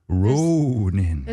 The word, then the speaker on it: Rōnin: Rōnin. – There's a character on top of that first O. – That character means you got to say it like a horse.